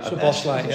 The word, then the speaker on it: Szoboszlai: Give us the correct pronunciation. – I forgot it now. I say Szoboszlai, but I don't think it is. Is it Szoboszlai? – Szoboszlai, yeah.